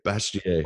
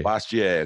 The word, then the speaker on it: Bastiat: And, you know, the word that I would say in that Frederick Bastiat, how do you say that in French? – Bastiat. – Bastiat, okay.